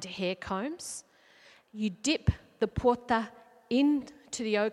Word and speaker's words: pwoja: Pedro Wonaeamirri is the use of what's called a porta, and it's spelt P W O J A. Once again, the linguists aren't very helpful, it doesn't really help us say it. I believe you say it porta,